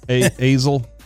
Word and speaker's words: Astle: The play-by-play man's name is Brandon and then his last name is A-S-T-L-E. How would you pronounce that? – Azel.